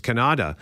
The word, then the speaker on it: Canada: The artist's name is Yemi TPX, and in the song, the word Canada is pronounced differently. It's pronounced Kannada, uh, just like Afrikaaner is pronounced yeah.